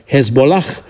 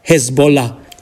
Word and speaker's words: Hezbollah: Dar mult mai aproape de noi să amintim acel H, H final, pur grafic, care nu se aude, din Hezbollah, partidul șiit fundamentalist libanez. Foarte mulți pronunță acolo H-ul final, Hezbollah, când, de fapt, sonor e doar Hezbollah.